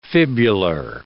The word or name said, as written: fibular